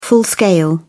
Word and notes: full-scale: British English

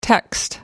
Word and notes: text: American English

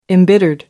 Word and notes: embittered: American English

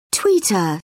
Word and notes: tweeter: British English

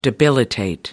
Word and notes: debilitate: American English

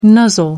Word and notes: nuzzle: American English

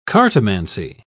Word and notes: cartomancy: British English

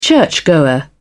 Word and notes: churchgoer: British English

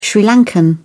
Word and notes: sri lankan: British English

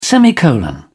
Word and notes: semicolon: British English

